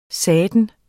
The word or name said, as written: satan